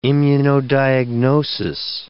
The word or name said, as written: immunodiagnosis